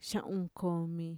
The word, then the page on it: xa'unkomi: Mixtec